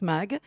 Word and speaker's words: Maeght: Ben voilà, je ne connaissais pas Nice et j'en ai profité pour aller visiter la fondation Paul Mag à Saint-Paul-de-Vence. On dit MAG, hein. Ça s'écrit M-A-E-G-H-T et ça se prononce MAG.